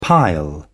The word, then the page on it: pile: British English